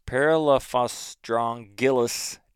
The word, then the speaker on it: Parelaphostrongylus: Paralophostrongus. Wait, nope, already screwed it up. Parelaphostrongylus tenuous.